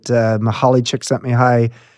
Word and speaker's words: Csikszentmihalyi: tap into that river that Mahali Csikszentmihalyi wrote about in Flow, his book, famous book.